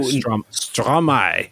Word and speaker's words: Stromae: Stromae, which is maestro with the syllables swapped.